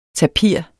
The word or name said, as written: tapir